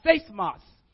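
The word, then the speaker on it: seismos (σεισμός): In the Greek, it is it's pronounced seismos. It's when we think about seismos, it's used. We get our English word seismic from this word.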